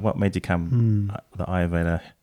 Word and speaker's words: Ayurveda: And today I'd like to focus on what you know around Ayurveda.